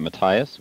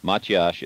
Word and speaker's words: Matthias: And just to let you know about your pronunciation, we pronounce it Matthias, which is an American pronunciation, but it's a Hungarian name, and Matyash is actually the Hungarian pronunciation. So you got closer to the original than most people do.